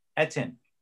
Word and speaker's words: Etienne: The top two, Najee Harris and how do you say Etienne's name? Etienne? – Etienne.